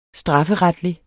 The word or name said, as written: strafferetlig